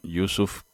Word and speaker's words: Yousuf: Quería empezar yo con Yusuf Karsh. Voy a deletrear su nombre y su apellido por mis típicos problemas de pronunciación precisa. Y-O-U-S-U-F, Yusuf, y el apellido es K-A-R-S-H. Karsh, Yusuf Karsh,